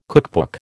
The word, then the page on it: cookbook: American English